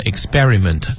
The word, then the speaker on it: experiment: 好，这个单词呢，咱们先说一下读音，虽然是 exp p for Peter，但是读音的时候它浊化了，不念成 expi 而念成 expi。 – 嗯，所以呢，拼写和读音它是有一些技巧的哈。你看这个 experiment 读是这么读，但你拼写的时候不能把 p 写成 b。